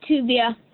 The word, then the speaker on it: Tuvia: You got it right, Tuvia. – Okay, all right. – Okay, how you spell it? T-U-V-I-A? – Yeah.